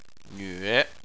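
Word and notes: nve3: tone 3

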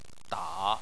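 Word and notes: da: tone 3

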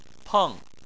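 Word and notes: peng4: tone 4

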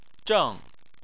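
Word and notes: zheng: tone 4

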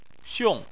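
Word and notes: xiong: tone 4

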